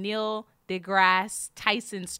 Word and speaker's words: Neil deGrasse Tyson: I just somehow got on Neil deGrasse Tyson's Twitter.